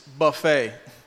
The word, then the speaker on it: buffet: A lot of people mispronounce that, and it's buffet.